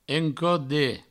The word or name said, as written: encode